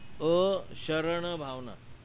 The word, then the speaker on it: अशरण भावना: देखो अशरण भावना ऐसा नहीं, अशरण भावना,